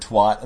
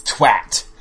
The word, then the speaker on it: twat: I remember, weren't you telling me somebody you knew somebody who was British, and you had said the word twat, and they were like, "It's twat." – Oh yeah, my fiance's friend. Yeah, he was making fun of the way Americans say twat, twat, twat. It's twat.